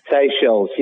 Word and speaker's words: Seychelles: How do you pronounce it? Is it Seychelles, the islands? – Seychelles, yes. Yep.